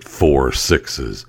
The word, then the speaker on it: 6666: So it's a 6666 ranch, and they pronounce it 4-6s.